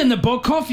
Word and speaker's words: Nabokov: Good on you, Nabokov. You're a real brain box. Boom. – You pronounced his name correctly.